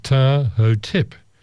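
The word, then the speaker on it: Ptahhotep: His name, and once again I had to get to the internet to get the pronunciation of it, the pronunciation is much easier than the word looks. The name of this gentleman is Tahotep.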